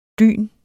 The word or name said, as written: dyn